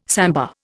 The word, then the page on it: samba: Indian